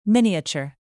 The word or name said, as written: miniature